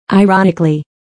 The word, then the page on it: ironically: airɑ’nikəli; アイロォニクリィ